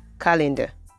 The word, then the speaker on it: calendar: And the final word is calendar, calendar, calendar, calendar. The stress is The first syllable is what is stressed.